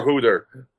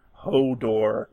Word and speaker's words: Hodor: Hooter is how I pronounce his name because I'm, an awful person. – Oh. Well, considering how it actually resolved, spoiler alert, Hodor is the correct yep.